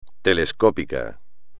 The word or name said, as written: telescópico